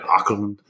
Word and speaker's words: Åkerlund: This movie was directed by Jonas Åkerlund. – Yes. – He's from Sweden, so I'm sure I butchered his name. But Åkerlund.